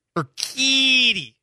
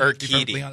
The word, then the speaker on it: Urquidy: I'm going to always butcher the pronunciation of that name. I think about it in my brain, and I'm like, wait a second, what? Hang on. Urquidy. Kind of like pronounce- Nailed it.